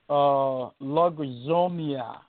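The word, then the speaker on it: logizomia (λογίζομαι): This word here is pronounced logizomia, logizomia, and in the Greek, L-O-G-I-Z-O-M-A-I, and it is pronounced L-O-G-I-D-Z-O-M-A-H-E-E. That would be the pronunciation in the Greek.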